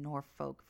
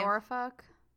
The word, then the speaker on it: Norfolk: The first American test tube baby, Elizabeth Jordan Carr, was born in Norfolk, Virginia. In 85. – Isn't it Norfolk? – Norfolk. I don't fucking know. I think it's pronounced Norfolk.